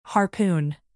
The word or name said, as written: harpoon